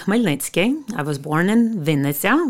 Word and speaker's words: Khmelnytskyi: It's Khmelnytskyi. – Yeah, and you spelled this for me earlier, but Khmelnytskyi. – That's correct.